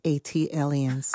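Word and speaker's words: ATLiens: That is pronounced Atlians. – Ah, thank you. – Like Atlanta alien, Atlians.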